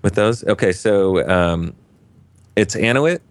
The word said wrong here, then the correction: Adonit: I think it's how you pronounce the name of the company. I'm probably getting it wrong. I got it right in my interview. – Adonit? Adonit? – Adonit. That's right. I left the D out.